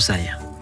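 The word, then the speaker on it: Cy: Donc, on peut que vous inviter donc à frapper à la porte et à aller voir Sai. Ça s'écrit C-Y, mais son vrai prénom, c'est Julien.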